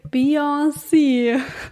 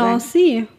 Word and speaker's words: Beyoncé: Weil mich stört dieses Hm in der Mitte. – Hm. Dieses, weil das, das schreibt man ja nicht. Beyoncé. Also ich sag. Es ist nur ein Hm eigentlich. Ich sag Beyoncé.